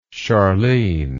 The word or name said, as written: Charline